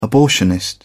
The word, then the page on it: abortionist: British English